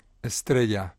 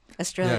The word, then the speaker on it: Estrella: The singer on this version of Lily that we're going to hear is Estrella Morente. Am I saying that right? – Estrella. – Estrella. – Yeah, Estrella, who means a star.